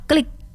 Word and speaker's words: KLIK: Oke, jadi kita ada istilah klik. Jadi KLIK, kita bacanya klik.